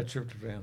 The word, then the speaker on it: tryptophan: I feel like you commonly see GABA and the, is it tryptophan? How do you say that one? – Yeah, tryptophan.